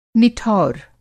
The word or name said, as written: निठौर